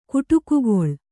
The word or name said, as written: ಕುಟುಕುಗೊಳ್